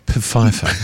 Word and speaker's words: Pfeiffer: It's pronounced Pfeiffer. – Must put the P in.